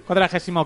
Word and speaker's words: cuadragésimo: Y hasta aquí el cuadrigésimo octavo. ¿Sí? Cuadragésimo octavo programa de Caviar Online.